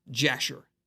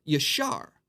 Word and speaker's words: Yashar: Now, it's not pronounced Jasher. There's no J sound in the Hebrew. It's the book of Yashar, which means the book of the upright.